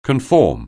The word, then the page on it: conform: anglais